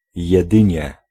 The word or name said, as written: only